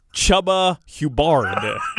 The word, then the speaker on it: Chuba Hubbard: And you're a big fan of Cahuba Hubbard, too. – He ain't said his name right all week. – I believe it's Chuba Hubbard.